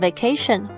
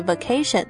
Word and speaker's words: vacation: 不过在这里提一下，在英式英文里的 vacation，不论你是念 vacation 或是 vacation，两种的发音都可以。